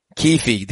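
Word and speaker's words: Kuithe: Can you pronounce it for me? – Brant Keithy. – Keefe. The emergence of Keefe, I think, is really taking this offense.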